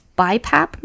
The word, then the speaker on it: BiPAP: I sleep at night with a BiPAP machine.